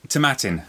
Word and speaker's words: Tomatin: Yes, so, Tomatin. It rhymes with satin. That's how you remember Tomatin.